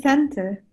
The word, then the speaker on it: centre: Center is C-E-N-T-R-E. – So, I would spell it C-E-N-T-E-R, center. – And to be honest, it makes no sense. Because, yeah, the sound is like E-R, not R-E, but it's just how they write it.